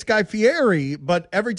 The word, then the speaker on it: Fieri: It's Fieri. It's Fieri. See, in Italian, E-R and I is rolled. It's Fieri. It doesn't have a T in it. Everybody wants to put a T in it, but it's Fieri.